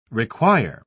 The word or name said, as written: require